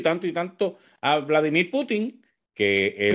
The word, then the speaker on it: Putin: Pero bueno, en otro tema, digo, antes de entrar en el tema que quiero traer, leí que el doble de Vladimir Putin, o Putin, porque no lleva acento en la I, pues teme por su vida, ¿verdad?